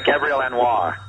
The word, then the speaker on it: Gabrielle Anwar: Am I saying her name right? – Yes. – Yeah, Gabrielle Anwar.